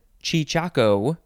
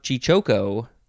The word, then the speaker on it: cheechako: The first word is chichaco or chichoco. That's basically it. It is spelled C H E E C H A K O.